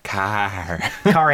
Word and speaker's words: Kaare: And the director, K-A-A-R-E. How would you say that? – Carr Andrews.